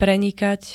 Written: prenikať

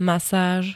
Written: masáž

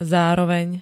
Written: zároveň

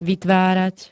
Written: vytvárať

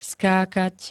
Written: skákať

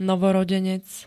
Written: novorodenec

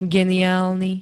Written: geniálny

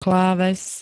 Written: kláves

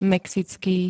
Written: mexický

